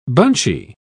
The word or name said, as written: bunchy